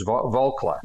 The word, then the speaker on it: Volcker: That was the somebody told me I was pronouncing his name wrong. It's Volcker.